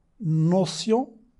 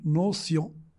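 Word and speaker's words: notion: مثلا في اللغة الفرنسية نوسيون، هنا سين، نوسيون سين أو صاد لا فرق، لكن في الكتابة نوتيون، يعني التين تاء، مرة تنطق سين مرة تنطق تاء.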